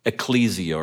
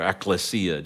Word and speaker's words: ekklesia: The word church is the Greek word ekklesia or ekklesia, depends on how you want to pronounce it, and it means called out or sent out.